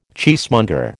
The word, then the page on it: cheesemonger: American English